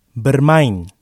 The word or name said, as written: bermain